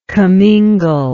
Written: commingler

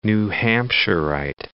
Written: New Hampshirite

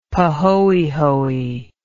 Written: pahoehoe

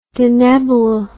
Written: Denebola

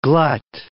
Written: glut

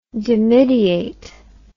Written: dimidiation

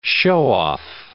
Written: show-off

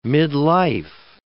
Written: midlife

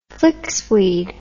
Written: flixweed

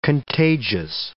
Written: contagious

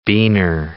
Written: biner

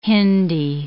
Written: Hindi

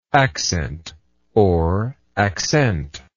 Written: accentuable

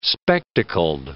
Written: spectacled

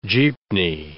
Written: jeepney

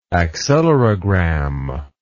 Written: accelerogram

